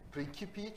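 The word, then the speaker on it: Principii: Principi çünkü klasik latincede C'ler K okunuyor.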